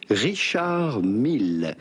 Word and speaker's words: Richard Mille: Do not pronounce the D to Richard. It's not like in English, you do not say Richard, but rather Richard. In English, Richard Mille. Do pronounce the double L. Richard Mille, with the typical French or Swiss French pronunciation, Richard Mille.